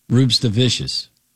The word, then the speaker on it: Rupštavičius: Emphasize the S. Emphasize the C. – Yes. – Rupštavičius.